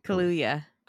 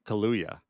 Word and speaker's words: Kaluuya: Kaluuya. – Kaluuya.